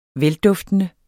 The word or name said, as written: velduftende